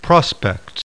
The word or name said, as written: PROSPECT